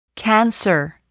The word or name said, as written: cancer